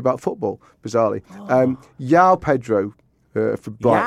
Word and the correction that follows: João: João. – Who is Yao Pedro? – João. Is it João? – João Pedro.